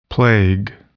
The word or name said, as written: plague